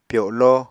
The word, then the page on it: pyolo: Apatani